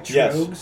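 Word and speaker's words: Tröegs: And the beer we've gone with is Trogues. I say it right? – Trogues. Yes, Trogues.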